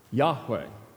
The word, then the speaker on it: Yahweh: Yahweh is the likely pronunciation.